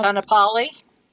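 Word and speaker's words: Kaanapali: It's spelled K-A-A-N-A-P-A-I-L. Kyle. – Kanapali. – Thank you. I love you.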